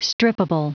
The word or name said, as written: strippable